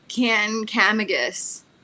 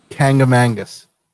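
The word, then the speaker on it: Kancamagus: Not even close. – No? – Nope. And the way that I have heard it pronounced and the way I pronounce it, I will let you know, it sounds like I add some letters. You want to hear how it's pronounced? – Yeah. – Kankamagus. – What? – Yep.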